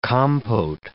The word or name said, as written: compote